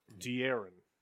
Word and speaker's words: De'Aaron: De'Aaron. – You guys will never be Caldwell Pope. That's what you're saying to them. – De'Aaron.